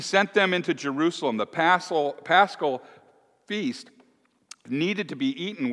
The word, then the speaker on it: Paschal: P-A-S-C-H-A-L, Paschal. I've been pronouncing it wrong. Paschal.